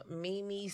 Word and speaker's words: Meme: I am Mimi Simpson. My name is spelled M-E-M-E, like Mimi. Not you. So it's Mimi Simpson on Facebook, and Twitter is it's Mimi Bitches.